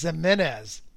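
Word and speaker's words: Giménez: It's Jimenez. Jimenez. – Jimenez. – Jimenez. – Defying all odds. – Like Jimenez with a J, like Eloy Jimenez.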